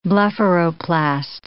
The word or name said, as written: blepharoplast